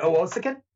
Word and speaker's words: Owosekun: O- Is it Owosekun? Yeah, the S is pronounced more like a SH.